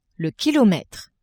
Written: kilometre

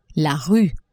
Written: rue

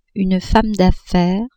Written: femme d'affaires

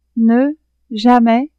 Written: ne-jamais

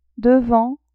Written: devant

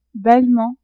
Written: bellement

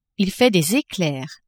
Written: il fait des eclairs

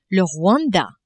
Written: Rwanda

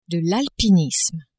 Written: alpinisme

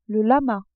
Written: lama

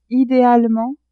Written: idealement

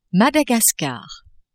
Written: Madagascar

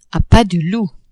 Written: a pas de loup

